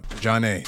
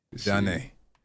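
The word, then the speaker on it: Zhané: Jeanne. Spelled with Z-H. Z-H-A-N-E. Jeanne.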